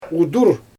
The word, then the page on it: udur: Xyzyl